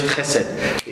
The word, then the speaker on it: chesed: The Hebrew word is chesed. Transliterated in English, chesed. The H has a, you got to get a little behind it so you can really say it right, okay?